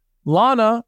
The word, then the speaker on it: Lana: Lana, rhymes with banana,